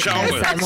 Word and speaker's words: Charlton: It was really good, apart from the T you pronounced in Charlton. I think it was Charlton, sorry, yes. That's right, yeah. – Thank you. There is no T in Charlton.